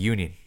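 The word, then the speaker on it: Junín: J-U-N-I-N. – N-I-N? – Yeah. – Junin? – Yeah. But I don't think you pronounce the J in Spanish, no? – Oh, right. Okay. – So it'd be Yunin.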